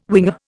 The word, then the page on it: winger: Indian